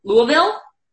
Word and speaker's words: Louisville: But, yes, I hope you're coming to – do I say it right when I say Louisville? Louisville, Kentucky? – Yes.